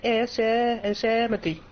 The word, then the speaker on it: Yosemite: Het is Yosemite. De klemtoon ligt waarschijnlijk op de Yosemite. Yosemite.